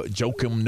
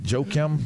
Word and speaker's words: Joakim: yeah, I mean, yeah, no, jo- Joakim Noah. I, I, I just think that that's kind of is that how you say it? Joakim Joakim Noah. – Yeah, how do you say it though? – Joakim.